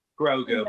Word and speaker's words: Grogu: Now let me also ask you guys about the whole Grogu, or am I saying it right? Grogu, is that right? – Grogu. Yeah.